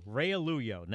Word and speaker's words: Realuyo: Realuyo.